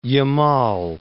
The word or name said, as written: Yamal